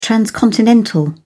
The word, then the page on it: transcontinental: British English